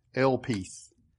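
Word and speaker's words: elpis (ἐλπίς): In the Greek, and I think I've got this in your notes, the word used for hope is elpis, E-L-P-I-S, just I's pronounced like a long E.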